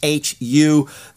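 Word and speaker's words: HU: The WHO, I've talked about them before, it's pronounced H-U.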